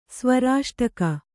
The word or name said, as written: ಸ್ವರಾಷ್ಟಕ